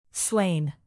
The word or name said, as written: slain